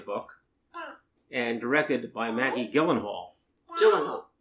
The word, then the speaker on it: Gyllenhaal: So he says it really is pronounced like Ilhali, Ilhal. And uh, Jake says, "Yeah, that's right." He says it's interesting. The only two places I see it pronounced correctly are Sweden and IKEA.